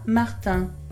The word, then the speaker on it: Martin: Je m'appelle Alexa Martin.